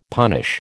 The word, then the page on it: punish: American English